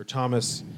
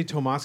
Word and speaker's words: Tomas: And the film really is a kind of character study on how this guy, Tomas, or Thomas, I keep wanting to say Tomas because it's spelled the Spanish way, but it's, yeah.